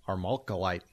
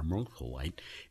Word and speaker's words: armalcolite: We ask you, for whom the mineral armalcolite was named? Hey, that wasn't bad, was it? – You did very well there on pronunciation, yes.